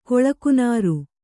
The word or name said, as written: ಕೊಳಕುನಾರು